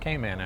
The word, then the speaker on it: Cayman: Cramer Islands. – It's pronounced Cayman actually.